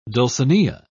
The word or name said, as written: dulcinea